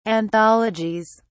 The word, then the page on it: anthologies: British English